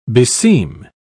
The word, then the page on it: beseem: British English